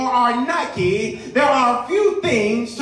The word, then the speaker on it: Nike: The word Nike, pronounced in Greek, Nike, is Greek for the word victory.